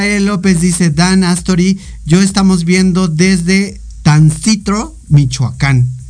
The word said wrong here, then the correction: Tancítaro: A ver si lo dije bien. Tancitro. Taro, Michoacán. No sé si dije bien. Tancítaro, Michoacán.